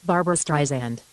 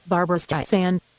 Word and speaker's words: Barbara Streisand: Okay, Barbara Streisand. – Okay, the last syllable should be pronounced with an S, not a Z. – Barbara Streisand.